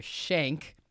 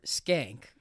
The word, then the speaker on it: Schenck: But, however, there's two pronunciations that people typically with my name use. It's either Schenck, like you're getting violently shanked. – Uh-huh. – Or Schenck, like, oh, she's Schenck.